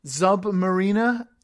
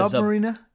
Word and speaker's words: Zub Mariner: Zub Mariner. – Like Submariner. Zub Mariner. But with a Z.